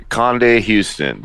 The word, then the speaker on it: Conde-Houston: Conde-Houston, yes.